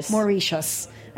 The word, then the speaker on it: Mauritius: Mauritius.